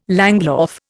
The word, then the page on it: langlauf: Indian